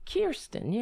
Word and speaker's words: Kyrsten: It makes absolutely no sense for him in particular and Kyrsten Sinema. I don't know. I keep saying her name wrong. I know. Is it Kyrsten? Because I have Kyrsten Gillibrand here. So. Kyrsten Sinema. Sinema. Kyrsten, yeah. Okay. K-Y-R.